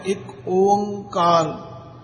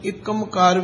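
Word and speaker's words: एक ओंकार (ਇੱਕ ਓਅੰਕਾਰ): कुछ लोग अनजाण पुणे विच इस नू एकमकार भी पढ़ते ने, कोई एकमकार भी पढ़ते ने। इसलिए इसका उच्चारण सानू सारिया नू चंगी तरह समझ के दृढ़ कर लेना चाहिए। पहले बोलो एक, फिर ओंकार, इसका शुद्ध उच्चारण है।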